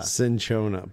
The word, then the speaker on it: Cinchona: Cinchona, but it's not. It's spelled C-I-N-C-H-O-N-A.